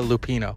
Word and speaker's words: Lupino: It was Ida Lupino.